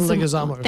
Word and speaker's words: Leguizamo: John Leguizamo.